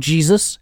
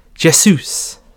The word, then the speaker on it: Jesus: He's not called Jesus, and he's not called Jesus. His name is Jesus. – Jesus. – It's also, it's the Portuguese translation, so J is a Z sound.